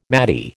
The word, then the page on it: maty: American English